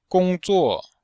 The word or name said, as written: gong1zuo4